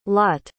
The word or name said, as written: Lotte